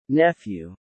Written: nephew

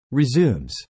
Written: resumes

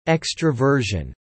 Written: extroversion

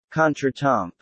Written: contretemps

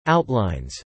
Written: outlines

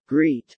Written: greet

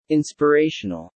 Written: inspirational

